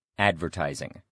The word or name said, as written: advertising